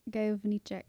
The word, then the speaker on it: Gajowniczek: Franciszek Gajowniczek.